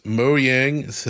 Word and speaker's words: Mojang: And, you know, maybe we do, though, because Mojang, that's how you say it, the Minecraft company, and King, Candy Crush people, they're now reporting directly to Asha.